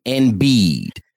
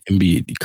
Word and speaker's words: Embiid: Embiid. – Embiid. – Joel Embiid. – Embiid, correct.